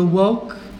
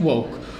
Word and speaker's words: woke: Primeiro que tudo, explicar-vos o que é isto do movimento woke. Woke, pronuncia em inglês book, como um termo político de origem afro-africana, americana, estás-me a desconcentrar.